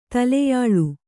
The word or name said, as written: ತಲೆಯಾಳು